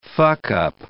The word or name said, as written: fuckup